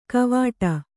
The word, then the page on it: ಕವಾಟ: kavāṭa